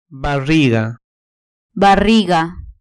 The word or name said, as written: barriga